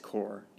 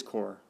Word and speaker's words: Corps: Corps? Is that pronounced Corps? That's like one of those things you get tripped up on. That's pronounced Corps, right? I probably just sound, it's dumb. C-O-R. Court pronounced. Yep. Maybe I can hear it here. Can we hear it? It's core. It is core. Like the Peace Corps.